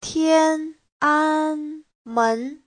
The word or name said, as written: tian1an1men2